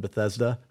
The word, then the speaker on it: Bethesda: like the dude of the waters of Bethesda.